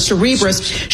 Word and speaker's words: Cerebras: How do you say your name? Like Cerebral. So Cerebras shares soaring.